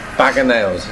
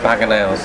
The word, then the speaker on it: Bag O' Nails: Well, this has been the Bag of Nails. Is that what I'm saying that right? – Yeah. – Bag of Nails.